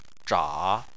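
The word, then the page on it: zha: tone 3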